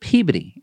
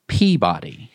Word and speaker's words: Peabody: I saw that Mark asked in the comments why we pronounce it Peabody and not Peabody in Massachusetts.